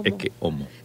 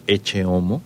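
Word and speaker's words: Ecce Homo: O sea, se lo pronuncian como Eche Homo. Uh-huh. Incluso en España lo dicen S o Exe Homo. Pecar de que me equivoque, pero creo que lo correcto en latín es Eque Homo.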